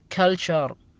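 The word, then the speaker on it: culture: مثال عليها كلتشر، مثال عليها شوفوها بالأسفل تحت، اللي هي معناها ثقافة.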